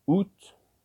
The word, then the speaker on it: août: August is pronounced août, août. September is pronounced septembre, septembre.